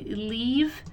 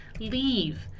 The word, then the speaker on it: leave: E é importante a gente reparar muito também na pronúncia desse leave, que é um leave bem... leave. Ele tem um som mais parecido com o nosso I do português, só que um pouco mais prolongado.